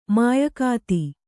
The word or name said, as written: ಮಾಯಗಾತಿ